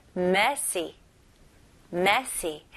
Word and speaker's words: messy: 근데 중요한 거는 강세입니다. 여기서는 첫 음절에 강세를 주시면 돼요. 그래서 messy가 아니고 messy, messy 해볼게요.